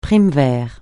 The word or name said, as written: primevère